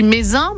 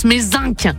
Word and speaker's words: Mézenc: Et en plus, j'ai dit Mézenc, mais est-ce qu'on prononce Mézenc alors? – Mézenc, non. – Oui, mais oui, c'est bien ça.